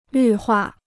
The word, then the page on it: 绿化: lǜ huà